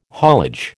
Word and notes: haulage: American English

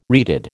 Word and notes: reeded: American English

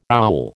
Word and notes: owl: American English

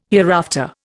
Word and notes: hereafter: Indian

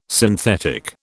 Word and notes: synthetic: British English

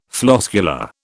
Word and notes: floscular: British English

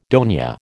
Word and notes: dona: American English

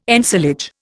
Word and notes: ensilage: Indian

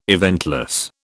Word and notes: eventless: British English

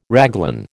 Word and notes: raglan: American English

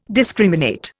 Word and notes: discriminate: Indian

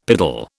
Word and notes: piddle: British English

